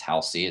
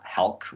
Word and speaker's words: Halsey: This is by Halsey, which we just discovered is pronounced Halsey. It's not Halk, which is how I've usually pronounced it.